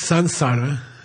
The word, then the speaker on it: samsara: Mispronounced samsara, okay? You can't have an M in Sanskrit before an S, okay? Never. Okay, it's samsara, like a French N, samsara.